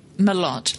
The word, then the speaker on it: Mellott: Mellott.